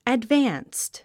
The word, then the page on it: advanced: ædvǽnst; アドバァンスト